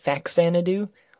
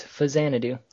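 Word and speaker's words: Faxanadu: Fizanidu? apparently that's how it's actually pronounced. I've always pronounced it Faxanidu, but apparently, according to Wikipedia, it's Fizanidu.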